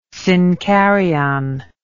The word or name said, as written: synkaryon